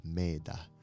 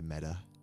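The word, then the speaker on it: metta: If you don't know what meta means, it's actually pronounced meda, but we just say meta, and it means loving kindness, benevolence, goodness.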